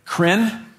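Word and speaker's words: krin (κριν): That V is actually pronounced N in the Greek, okay? So it's not kriv, it's krin.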